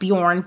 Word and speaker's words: Bjorn: It's pronounced Bjorn, but it's actually spelled B-J-O-R-N.